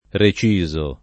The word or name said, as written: reciso